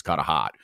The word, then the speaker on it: Hart: And not saying Kata Hot, or Carter, instead of saying his name properly, it was Kata Hot.